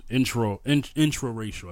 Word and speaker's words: intra-racial: They call those intra-racial issues. Intra-racial, I think I'm saying it right. – Intra-racial, yeah. – No, mm-hmm. not interracial, but intra-racial, meaning within the race.